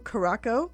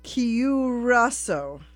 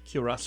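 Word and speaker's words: Curaçao: I was saying blue curacao. – Yeah. – And it's curacao. – Curacao. – Curacao. Blue curacao.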